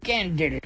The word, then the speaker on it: Gandalf: How do you pronounce G-A-N-D-A-L-F? And he said, Gandalf. I said, excuse me? Gandalf. It rhymes with, like, health. As in, half sixpence. So in American, it would be Gandalf.